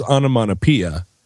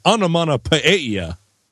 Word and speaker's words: onomatopoeia: And I, of course, raised my hand and was like, I've heard that a lot as onomatopoeia. And he leapt up on his desk and he was like, exactly wrong. Everyone pronounces that word wrong. It's onomatopoeia. So